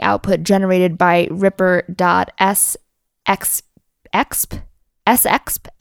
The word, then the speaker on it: sexp: Uh, generated by Ripper.sexp expression, which I think is much better uh, when it's pronounced sex p.